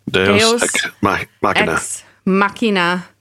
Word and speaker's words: Deus ex machina: You know, like when people say Dios mio, my God, Deus. It's the same thing. Deus.